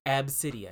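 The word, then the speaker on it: Abcde: And it's pronounced Ab City, I believe. – See, it sounds like you're saying like Ab City. – Yeah, well, that's when I read the article. It said the name is spelled, and they spelled as the pronunciation A-B hyphen city.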